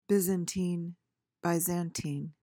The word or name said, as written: byzantine